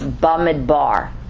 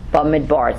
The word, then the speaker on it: Bamidbar: It's Bamidbar. Bamidbar. It's spelled B, as you'll see in your handout, apostrophe, M-I-D-B-A-R. And it means in the desert, in the wilderness. And you have to say it like that because that sounds pretty depressing, doesn't it?